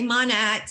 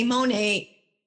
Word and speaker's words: Monat: Okay, so it's Mani, like modern nature put together, but you'll hear different pronunciations from different people. Some people say Monat, some people say Monet.